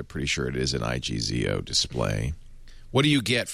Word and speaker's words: IGZO: And it's pronounced IGZO. Is that right? – Yeah.